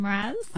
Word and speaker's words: Mraz: Jason Mraz.